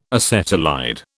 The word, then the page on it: acetylide: British English